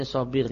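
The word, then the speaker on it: Sabigh: Oleh karena itu, bamin sama dari sana, maka Umar mengingkari sobir, bacanya sobir ya.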